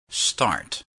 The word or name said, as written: start